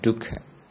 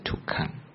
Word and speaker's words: dukkha: In Pali it's called dukkha. In Burmese pronunciation it's called dukkha. In Thai you say tukkan or tuk. In Sri Lankan pronunciation is dukkha.